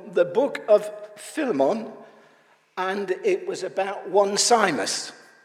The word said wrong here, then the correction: Onesimus: Now, if you see Onesimus written, that's how you should pronounce it.